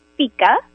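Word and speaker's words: fika: Well, I am going to say it's actually pronounced FICA. – FICA. Ah. Oh, yeah. Well, that would be my bad. – Don't worry. I have a lot of people call it FICA, but it is pronounced FICA.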